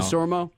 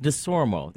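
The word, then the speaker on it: Desormeaux: DeSormo? – Kent DeSormo. Yeah, that's correct.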